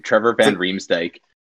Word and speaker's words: Van Riemsdyk: Trevor Van Riemsdyk.